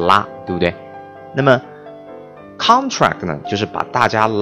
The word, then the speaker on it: contract: 啊，当然做动词的时候啊，这个词要读成 contract 啊。那么这个是符合英文当中啊重音位置名前动后这样的一个规律。